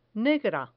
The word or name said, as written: negra